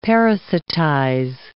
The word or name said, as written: parasitize